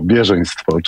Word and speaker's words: bieżeństwo: To jest bardzo ważne, żeby mówić z akcentem na pierwszą sylabę. Tak się tutaj przyjęło, bieżeństwo,